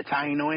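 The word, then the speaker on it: Taíno: And I think if I said it right, you're saying Taino, right? – Yeah, Taino Indians.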